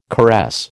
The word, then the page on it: caress: American English